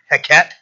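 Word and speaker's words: Heqet: It's H E Q E T Heket.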